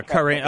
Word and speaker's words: Karine: Oh yeah, yeah, yeah, Karine. Uh, yeah, Karine. It's yeah, right. It's spelled differently than it's pronounced.